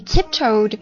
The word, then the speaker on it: tiptoed: 我们说过去式的话通常加 ed 嘛，对吧？那原单词有了 e 的话呢就只加 d 了，所以它的读音是 tiptoe 的的的，读个的音啊，因为这个 ed 前面它是 o 元音发音。 He tiptoed to the door, 他蹑手蹑脚的走到了门边，是什么状态呢？